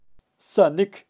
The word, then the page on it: sanik: Kaqchikel